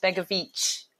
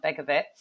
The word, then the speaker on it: Begovic: So Asmir Begovic is pronounced Begovic at the end and not Begovic.